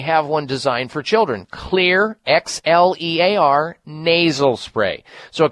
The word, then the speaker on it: Xlear: There's one called Clear Children's Nasal Spray, and Clear is spelled X-L-E-A-R.